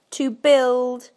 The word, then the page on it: to build: British English